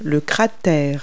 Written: crater

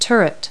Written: turret

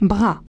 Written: arm

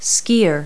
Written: skier

